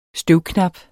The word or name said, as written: støvknap